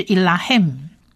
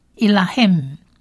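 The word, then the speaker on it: Elohim (אֱלֹהִים): 希伯来文字母 Man 放字尾发鼻音的音，所以变成整体的整个字发音就是 Elohim Elohim Elohim 是他参战或他攻打，或者最简单你也可以讲说他征战，他征战也可以。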